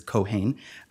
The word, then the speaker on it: Cohane: But yes, I'd like to move now to Cohane, and I believe from listening to the oral arguments, it's pronounced Ms. Cohane.